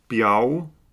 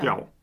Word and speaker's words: bjerg: Und der Berg, der Bjau, geschrieben B-J-E-R-G, der spricht sich als Au aus, also ja. Bjau.